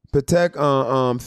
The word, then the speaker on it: Patek: How's it pronounced? Patek? – Patek, Philip watch.